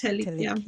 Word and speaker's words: Telic: Am I pronouncing that right? – Telic, yeah.